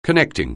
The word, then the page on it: connecting: anglais